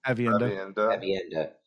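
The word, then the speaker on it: Aviendha: And I don't think she was boasting, but Evanda, how do we say her name again? – Avienda.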